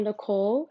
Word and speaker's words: Nichole: For those of you who don't know me, I guess personally, I'm Nichole. Again, I spell my name differently. I am a unique gal. I spell it with an H, N-I-C-H-O-L-E.